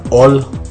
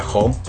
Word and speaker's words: Hull: Entonces, para la jornada 14 se enfrentó el Middlesbrough le gana 1-0 al All, porque parece que así lo pronuncian los ingleses. En inglés sería aquí, el americano sería Hull.